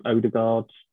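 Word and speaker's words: Ødegaard: Yeah, with Arsenal, obviously their main attackers are Smith Rowe, Saka, and I can't pronounce his name, Odegaard.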